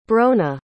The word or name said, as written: bronagh